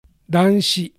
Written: Danshi

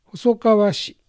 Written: Hosokawashi

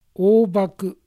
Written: Obaku

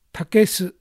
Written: Takesu

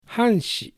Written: Hanshi

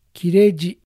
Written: Kireji

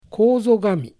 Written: Kozogami

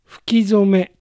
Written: Fukizome

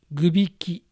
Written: Gubiki

